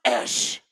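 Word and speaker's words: Esh (איש): The word man there comes from the Hebrew word, not Adam, but the word is simply I-S. It's pronounced Esh, and it doesn't mean low state.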